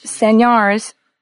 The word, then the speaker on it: señales: The word sign is pronounced señales in Spanish.